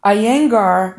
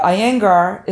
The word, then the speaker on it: Iyengar: Iyengar yoga is a relatively gentle form of the exercise that emphasizes precision and alignment. And this style of yoga, Iyengar, is spelled I-Y-E-N-G-A-R, yoga, Iyengar, I-Y-E-N-G-A-R, yoga.